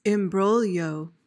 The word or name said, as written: imbroglio